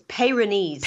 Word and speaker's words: Peyronie's: So it's Peyronie's.